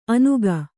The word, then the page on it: ಅನುಗ: Kannada